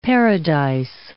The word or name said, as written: paradise